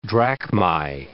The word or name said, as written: drachmai